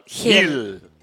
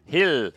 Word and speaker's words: Gil: Well, it's Carla Gil, or in your Mexican, so is it Carla Hill? – Hill. Hill. It's Carla, but it's spelled G-I-L. So us gringos would say Gil, but it's really Hill.